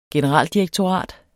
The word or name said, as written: generaldirektorat